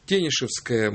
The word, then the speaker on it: Тенишевское: Правильный ответ – Тенишевское. Мы тут специально узнавали ударение, звонили в город Санкт-Петербург.